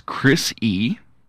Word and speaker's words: Chrissy: Christy Teigen. – It's Chrissy. – Chrissy? – Yeah, she doesn't spell it with a T.